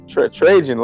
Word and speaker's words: Trajan: You can't butcher the Alaskan assassin's name, sir. The the original J.J. Redick before J.J. Redick, Bob. Trajan Langdon.